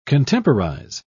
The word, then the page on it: contemporize: British English